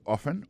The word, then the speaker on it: often: Often times, c'est O-F-T-E-N. Times, T-I-M-E-S, alors c'est comme ça on dit ça. Soit on dit often, often non, c'est O-F-T-E-N, malgré l'écrit often, vous prononcez often.